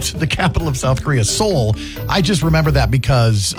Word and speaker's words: Seoul: S-E-O-U-L. – Yes, Seoul. It's pronounced Seoul, South Korea.